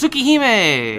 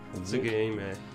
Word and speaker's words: Tsukihime: Tsukihime! – Yeah, Tsukihime.